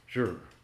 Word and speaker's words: Zhi: Hungjur is confusingly transliterated into English as H-O-N-G. That makes sense. Hung, right? H-O-N-G is the first character. Second character is translated Z-H-I, which is pronounced Zhur.